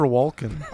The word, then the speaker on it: Walken: Who's Christopher Walken? – I've never heard of that guy. – I pronounce the L.